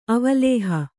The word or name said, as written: ಅವಲೋಹ